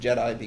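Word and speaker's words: GEDI: We have a technique that we call geometrically enhanced differential immunocapture, and we pronounce it JEDI because we like to make hints at Star Wars.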